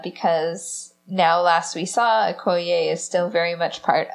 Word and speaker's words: Okoye: It sounds like the scenes that were deleted had a little bit more to do with our Okoye, I would say her name. – Okoye.